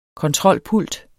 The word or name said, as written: kontrolpult